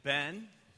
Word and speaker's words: Ben: Hey, my name is Ben. Rhymes with 10, hen, when, then, ESPN, and amen.